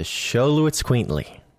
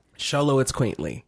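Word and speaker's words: Xoloitzcuintli: Sholowitzquintly. – If you get nothing else from this episode, you will know how to pronounce the most difficult to pronounce dog breed. – Yes. – Sholowitzquintly. Emphasis on quaint.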